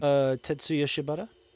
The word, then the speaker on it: Tetsuya Shibata: Tetsuya Shibata.